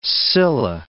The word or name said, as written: scilla